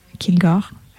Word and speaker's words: Kilgore: Alors, Ryan Kilgore s'écrit R-Y-A-N, plus loin, K-I-L-G-O-R-E.